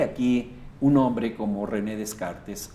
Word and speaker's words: Descartes: En realidad en español se pronuncia Descartes. Si quisiéramos pronunciarlo en francés sería Descartes. Pero bueno,